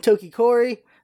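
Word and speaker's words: Tokkori: The show opens with a star wipe on Takori, is how you pronounce his name, sleeping in his nest, and we see a farm of sheep that gets attacked by a giant octopus.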